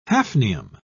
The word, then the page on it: hafnium: British English